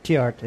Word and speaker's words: Theart: Did I say it right? Tiert. – Tiert, yeah.